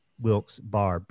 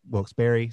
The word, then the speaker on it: Wilkes-Barre: And or Wilkes, and then like a saloon, as a way of saying that like there are so many different ways you can pronounce Wilkes Barre, B-A-R-R-E. And so some people are like Wilkes-Barre, some people are Wilkes-Barre, some people are Wilkes-Barre. And there's actually a fourth one that Lewis and I talked about, which is Wilkes-Barre, which is the way my granny would have said it. My granny would have said Wilkes-Barre.